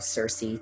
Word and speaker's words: Sirsy: I would always sign all of my cards, "Love Circe," to her. Only with her. It wasn't my nickname with everyone, just with her. And, you know, we were little kids, so we spelled it phonetically, S-I-R-S-Y.